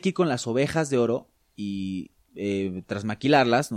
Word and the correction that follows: trasquilarlas: no sé cómo se dice. trasquilarlas. Trasquilarlas, perdón.